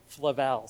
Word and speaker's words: Flavel: This guy, John Flavel, people pronounce his last name differently. Some people say Flavel, Flavel, but Sinclair Ferguson, the scholar, says Flavel. So that's what I'm going with, because if he says it that way, it's got to be right.